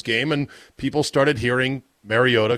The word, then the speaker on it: Mariota: Yes, I said Mariota, because that's apparently the correct way to pronounce his name.